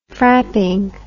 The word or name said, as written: frapping